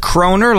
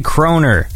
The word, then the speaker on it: kroner: Is the word kroner, like, how do you say kroner? – Kroner.